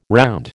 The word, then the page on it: round: American English